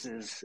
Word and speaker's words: James: Yes, James is how it's spelled. So I know in some countries it's pronounced Jaime, but James is fine.